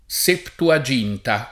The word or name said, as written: septuaginta